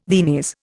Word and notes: Viennese: Indian

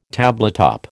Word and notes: tabletop: American English

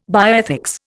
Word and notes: bioethics: Indian

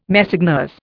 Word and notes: Messeigneurs: Indian

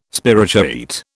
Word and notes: spirochaete: British English

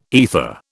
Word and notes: aether: British English

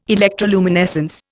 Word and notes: electroluminescence: Indian

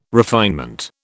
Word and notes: refinement: British English

